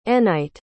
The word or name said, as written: enite